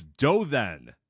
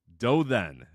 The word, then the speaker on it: Dothan: It's Dothan. Dothan is how you pronounce that.